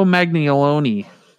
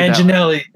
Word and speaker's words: Manganiello: Joe Manganiello. I butchered Manginelli.